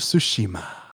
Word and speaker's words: Tsushima: And first, we'll talk about Ghost of Tsushi had their big gameplay reveal, which was exciting. – I'll actually say it right. It sounds weird when Adam messes it up. – Ghost of Tsushima.